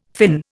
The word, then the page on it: Finn: Indian